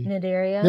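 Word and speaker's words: Cnidaria: But it's not pronounced with the C. – Nidaria.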